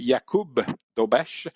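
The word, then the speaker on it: Jakub Dobeš: En fait, il faut prononcer Jakub Dobesh.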